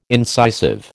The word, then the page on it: incisive: American English